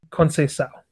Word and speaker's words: Conceição: Conceição.